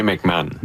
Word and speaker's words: McMahon: Sébastien McMahon, de l'Industrielle Alliance. – Parfait. Alors, merci de la prononciation. – Comme l'ancien corps arrière des Bears.